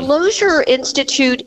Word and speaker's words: Lozier Institute: And before we get in the meat and taters of this, tell us a little bit about the Lozier Institute. Am I saying that correctly? – Yes, thank you for asking. So the Lozier Institute is the research arm of Susan B.